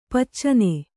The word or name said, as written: ಪಿಚ್ಚನೆ